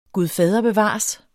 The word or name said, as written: gud